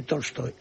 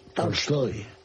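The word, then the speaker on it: Tolstoy (Толстой): No es exactamente Tolstoy, es Tolstoy. – ¿Cómo? Lo dije Tolstoy con A, una. – Embajador, lo dije así tal cual. Tolstoy. – Correcto, es una A que tira hacia el O, pero que es A.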